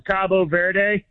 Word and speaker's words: Cape Verde: They in Spanish, it's Cabo Verde. – Cabo Verde, Cabo San Lucas or whatever in Mexico, Cabo Verde. – Okay. – But the English pronunciation is Cape Verde.